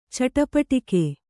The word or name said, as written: ಚಟಪಟಿಕೆ